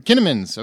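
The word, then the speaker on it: Kinnaman: Am I saying that right? Kinnamans? – Kinnamans, okay.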